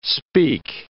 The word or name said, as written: speak